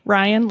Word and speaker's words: Ryan: And Rebecca is spelled R-E-B-E-C-C-A. Ryan, like the boy's name, R-Y-A-N.